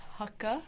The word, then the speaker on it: Haka: It's actually pronounced Haka. – Haka, right? – Haka, okay. – From Māori.